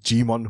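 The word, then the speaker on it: Djimon: Yeah, no, I always butcher his name. I think his name is Gmon Hunso. – Right, okay. – But it's spelled with a silent D.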